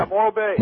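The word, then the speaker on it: Morro Bay: Yeah, Morrell Bay.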